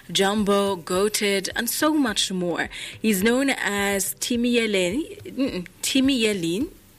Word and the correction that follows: Timi Leyin: No, I said it wrong. Timi Leyin. That's how you say his name.